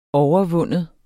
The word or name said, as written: overvinde